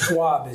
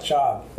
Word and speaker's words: Schaub: Schwab is the usual. – Schwab? – Schwab is the usual mispronunciation. – Okay. But Schaub. – Schaub.